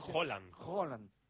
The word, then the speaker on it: Haaland: la pronunciación correcta es totalmente distinta. – Como Haaland. Sí, flaco. – Por ejemplo. – Claro, Haaland... – Es Haaland. – Como si dijeras un queso, ¿no? – ¿Sabes qué pasa el martes?